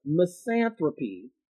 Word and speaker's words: misanthropy: Misanthropy. MISANTHROPY. Misanthropy. And I guess that would make me a misanthropist, which is the dislike of humankind. – Misanthropy.